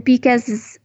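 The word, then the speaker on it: Vicas: And Bicas it's spelled with a V, although it sounds like a B, and it's the name of the owners.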